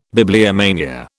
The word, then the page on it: bibliomania: British English